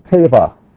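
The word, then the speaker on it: teva (טבע): The first one is teva.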